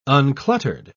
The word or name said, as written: uncluttered